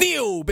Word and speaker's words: Nioh: Uh, it's Nioh, baby. It's uh, yeah, like hey.